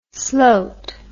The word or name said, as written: slote